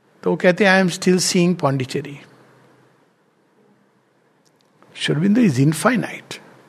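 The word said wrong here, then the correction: infinite: sorry इन्फिनिट अभी अभी मैंने नया नया प्रोनाउंसिएशन सीखा है इनफाइनाइट नहीं इन्फिनिट कहते हैं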